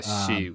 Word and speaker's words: Xi: Yeah, I was thinking about the profile you wrote of Xi. Am I saying that right? – G? Yeah. Yeah. She.